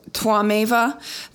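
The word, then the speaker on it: Tvameva: Um, all right, so Twameva. The first line is Twam Twameva. It's T W A, but pronounced like Twa, like a V W.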